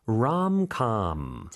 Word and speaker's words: rom-com: Rom-com. – 자, M이 다 붙습니다. 로코의 롬콤 이렇게 되는 거죠, 그렇죠? 근데 발음은 롬컴.